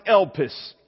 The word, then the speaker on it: elpis: It's a little word in the Greek we pronounce elpis, E-L-P-I-S.